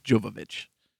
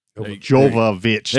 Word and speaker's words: Jovovich: It's Mila Jovovich. – Jovovich.